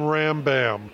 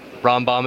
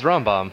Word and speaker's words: Rombaum: In Texas, I'm Ram Bam, as in thank you, ma'am. – Oh, man. – Well, at least it rhymes, but sadly, Rombaum is Rombaum.